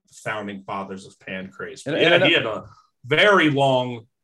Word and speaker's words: Pancrase: Pancrase? Is it spelled like pancreas? – It's P-A-N-C-R-A-S-E. – Pancrase. Oh, okay. So not pancreas. Not like the.